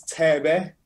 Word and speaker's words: Thebe: When I'm 6'7", the only thing I'm really hearing is Tebe, which is spelled Thebe. He's a legend, a Kwaito legend, but it's more dance. It's like house. So it's Tebe, Boom Shaka, TKZ.